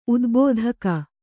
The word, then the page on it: ಉದ್ಬೋಧಕ: Kannada